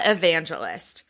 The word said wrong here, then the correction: Evangelista: Nope, that's not how you say it. Evangelista. Evangelista.